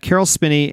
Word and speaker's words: Caroll Spinney: That means that Caroll Spinney and Diahann Carroll will join Robert Forster to round out our 16th apartment. Well, I would like to say that you did pronounce that correctly because I, in fact, know a person who made a customized cuckoo clock for Caroll Spinney when he retired from Sesame Street.